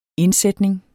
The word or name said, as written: indsætning